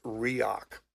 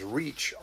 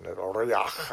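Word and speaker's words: Reoch: Now, people have often seen his name, and they think, understandably, that his name is Reach, R-E-A-C-H, because that's how it's spelled. But Angus was very particular about pronouncing it Reok, and so he spent his life correcting people about the pronunciation. He came from Inverness, and this was a Highland pronunciation, Reok.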